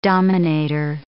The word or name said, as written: dominator